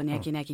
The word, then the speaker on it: Nyege Nyege: Well, I was desperate to go to this festival, Nyege Nyege. You did say it right.